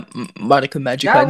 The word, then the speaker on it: Madoka Magica: Yeah, Madoka Magica, not Madoka Magica.